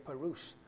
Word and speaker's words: parush: So P-R-S-H. But if you contextualize it in the Western language, it you can say parush.